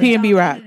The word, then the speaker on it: PNB Rock: One thing you ain't ever seen is this rapper, PNB Rock, or whatever. Okay. Yeah. Did I say it right? – PNB Rock, yeah.